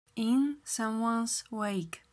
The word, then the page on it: in someones wake: slow